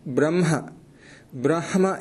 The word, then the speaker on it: ब्रह्म: उच्चारणार्थं वदामि यत् यत्र हकारस्य अनन्तरं नकारः अस्ति चेत् तत्र परम्परया वयं नकारस्य उच्चारणं प्रथमतया वदामः न तु अनन्तरम् उदाहरणार्थम् अत्र चिह्नम् इति अस्ति चिह्नमिति न इति अनुक्त्वा चिह्नमिति अनुक्त्वा चिह्नं तथैव ब्रह्म इति न ब्रह्म इति परम्परा अस्ति प्रथा अस्तु अग्रे